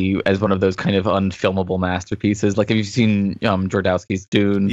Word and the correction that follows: Jodorowsky: Yeah, Jodorowsky's Dune.